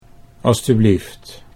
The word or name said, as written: alstublieft